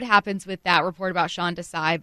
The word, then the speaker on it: Sean Desai: Am I saying this right? Sean Desai? – Yes. – Yeah, you are.